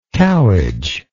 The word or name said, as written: cowhage